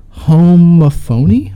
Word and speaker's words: homophony: Homophony? Homophony, is that how you say it? – It's homophony, but yeah.